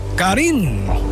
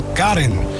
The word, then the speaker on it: Karin: Alleen de klemtoon leg je heel vaak anders. Bij de vrouwen, Karin. En bij de mannen, Karin. Dus Karin León en Busca.